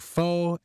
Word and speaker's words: faux: In the case of the X, silent X words, unless we made an embarrassing mistake, we are pretty sure that the letter X is silent in words like faux and faux pas, as it is in other French-derived words like roux and doux, and some other plurals like chou and riso, the plurals of chou and riso, respectively.